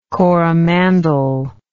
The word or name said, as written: Coromandel